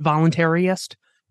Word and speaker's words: voluntarist: I pronounce it voluntarist because it uh, focuses on the word voluntary.